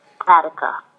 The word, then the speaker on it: Catica: It's spelled C-A-T-I-C-A Couture, Katika.